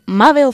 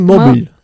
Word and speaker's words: mobile: Jackie's new mobile phone. – Mobile. ¿Cuántas veces tengo de decirlo? Mobile. No se dice móvil. Mobile. Es diferente.